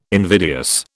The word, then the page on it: invidious: British English